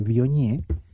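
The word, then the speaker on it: Viognier: Vionier se escribe, se pronuncia pues, pero se pronuncia Vionier que es, en mi concepto, es la más, la más versátil que he encontrado.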